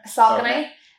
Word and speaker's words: Saucony: Saucony. – Wait, hold on. There is a correct way, because me and someone from my club discussed this week. Saucony.